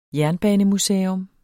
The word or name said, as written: jernbanemuseum